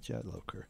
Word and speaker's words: Jadlowker: Yes, that's the way you pronounce it, Jadlowker.